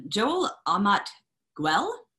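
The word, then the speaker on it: Joel Amat Güell: Joel Amat-Gwell, trying my best there. I'm pretty sure that that's how I was told his name is pronounced.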